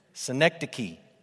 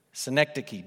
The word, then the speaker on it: synecdoche: Synecdoche. Synecdoche. Don't say it wrong, then they'll think you're stupid. Say it right. Synecdoche. I found that on the internet. I know that's how you pronounce it.